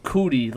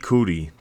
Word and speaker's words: Coutee: I cutie. think it's, it's, I think it's cootie, like cooties. – yeah, it's Keke Coutee. That's how you say it.